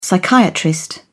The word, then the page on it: psychiatrist: British English